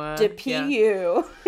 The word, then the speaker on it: Dupieux: I looked up how to pronounce Quentin Dupieux because I wanted to know, and Dupieux is pretty, I think it's a metaphor for how much this movie stinks. Because, like, Dupieux.